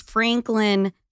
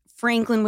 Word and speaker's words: Franklyn: My last name is Franklin, spelled with a Y. – Right. – So it's Sophia with an F, Franklin with a Y.